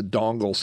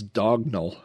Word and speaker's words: dongle: That's the term that we would use today as a dongle, something that would plug into a physical spot on your computer. I used to have a friend who constantly mispronounced that as dognal. That drove me crazy.